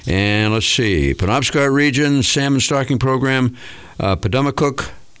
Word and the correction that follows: Pemadumcook: Did I say it right? – Pemadumcook.